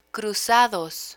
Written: cruzados

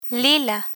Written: lila

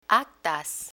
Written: actas

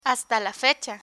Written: hasta la fecha

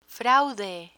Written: fraude

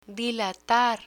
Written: dilatar

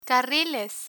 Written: carriles

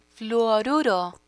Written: fluoruro

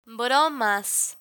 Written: bromas

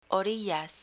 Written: orillas

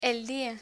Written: dia

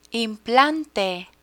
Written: implante

